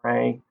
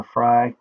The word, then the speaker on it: Frey: I've been saying Frey. I thought that's how it was pronounced, but it's actually Sarah Frey.